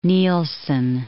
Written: Neilson

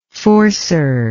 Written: forcer

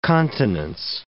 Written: continence

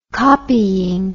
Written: copying

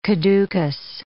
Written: caducous

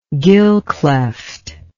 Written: gill cleft

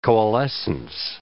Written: coalescence

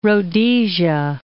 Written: Rhodesia

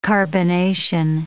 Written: carbonation

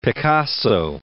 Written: Picasso

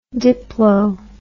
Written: diplo

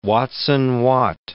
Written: Watson-Watt

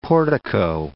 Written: portico

